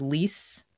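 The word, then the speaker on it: Leisz: The new EP, Downtown in the Rain, produced by Greg Leese. That's how you say it, right? – It wasn't produced by Greg, but he plays on it and you pronounced it perfectly.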